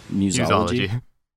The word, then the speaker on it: museology: Today we're going to be talking about art galleries, some museumology. How do you pronounce that? – Museumology? – Museology? Yeah, okay. You got it. Museology.